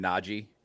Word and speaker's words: Najee: But in, in 2022, um, can we resolve to pronounce his name Najee? – Sure. Cool. We can. – Yeah. That's actually how he pronounces it.